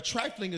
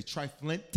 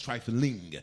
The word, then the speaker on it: trifling: Now, trifling is the appropriate pronunciation of the word. Some people say triflint. That's not the word. It's trifling, which means everything to you is trivial.